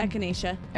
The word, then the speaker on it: echinacea: Not to mention the, I can't pronounce that word, echinacea. – Echinacea.